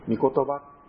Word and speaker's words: 御言葉: そして神様の手ということで、オン手と書いてミテと読んだり、あるいは一番これが聞かれると思うんですけども、オン言葉と書いてミ言葉、これは神様の言葉、時には聖書自体のことを御言葉とさせて呼んだりします。